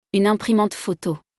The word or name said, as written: Une imprimante photo